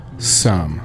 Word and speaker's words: Sum: Yeah, how do you pronounce it? Is it Sium? – Yeah, it's Sium? – Sum. – Okay.